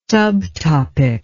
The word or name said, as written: subtopic